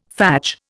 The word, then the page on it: fadge: Indian